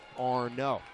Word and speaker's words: Arnaud: as a new baseball will be given to Arnaud. Last name spelled and pronounced the same as Travis d'Arnaud, former Mets catcher, now with the Atlanta Braves.